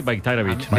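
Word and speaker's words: Bajraktarević: O sea, la J la conviertes en una I, Bayraktarevic.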